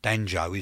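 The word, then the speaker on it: Django: This is Return of D'Angelo, and this is Upset Us. No, I think it's Danjo, isn't it? I pronounced it wrong.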